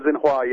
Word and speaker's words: Xinhua: Oh, Xinhua, yeah.